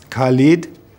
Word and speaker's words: Khalid: It's Khalid. – How do you spell that? – K-H-A-L-I-D.